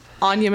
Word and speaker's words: Anja: I can be found at Anya Monique on Instagram, and my name is spelled weird. It's A-N-J-A.